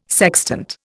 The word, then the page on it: sextant: Indian